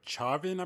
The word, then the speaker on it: Chauvin: The next article we have here is Der- I can't really pronounce his last name, but Derek Truvine is on trial for George Floyd's death. – Derek Chauvin, I think I believe is pronounced. Colvin, maybe that sounds like it might be it.